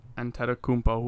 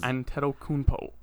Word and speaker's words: Antetokounmpo: Like, well, not only that, but, like, a guy like Giannis Antetokounmpo, who... – Nailed that pronunciation. – I did? – That was pretty good. You missed one N, but that was... – Antetokounmpo.